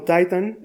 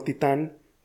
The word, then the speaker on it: Titan: Lo único que cambia es la posición del acento, así que puedes elegir. Pronunciarla como Titan es correcto, y como titán, también lo es.